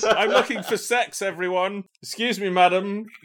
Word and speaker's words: CeX: I call it CEX. – It's officially called Sex. That's actually how it's pronounced.